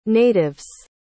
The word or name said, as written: natives